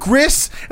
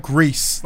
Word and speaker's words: Gris: I called it Gris, and then someone's like, "No, you guys pronounce it. It's like Greece, like as in like the country." I'm it's Greece.